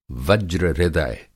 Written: वज्र-हृदय

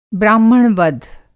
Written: ब्राह्मणवध

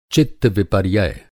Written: चित्तविपर्यय

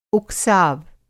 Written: उकसाव

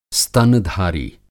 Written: स्तनधारी

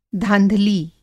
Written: धाँधली

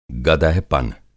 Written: गदहपन

